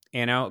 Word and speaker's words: Anno: Is it Anno or Anno? It's Anno.